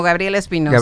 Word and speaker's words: Gabriel Espinosa: Como Gabriel Espinosa. – Gabriel Espinosa. – Con S, porque luego hay algunos apellidos que se escriben con Z.